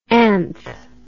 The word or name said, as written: anth